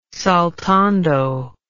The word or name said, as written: saltando